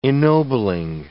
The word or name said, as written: ennobling